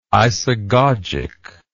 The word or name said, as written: isagogic